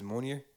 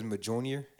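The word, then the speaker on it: Mjölnir: My coworker that was talking about Marvel, it's funny how he pronounces Mjolnir. Says Majonier.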